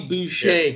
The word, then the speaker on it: Boucher: And then also, is it Boucher? Is that the right pronunciation? – Yeah, yes, just like Bobby Boucher.